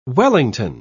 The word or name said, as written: wellington